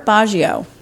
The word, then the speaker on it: Baggio: Robert Baggio. Am I saying that right? B-A-G-G-I-O? – Mm-hmm.